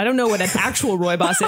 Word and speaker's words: rooibos: I am drinking raspberry rooibos tea. – That's how it's pronounced. Okay. – That is how. – Rooibos? – Rooibos.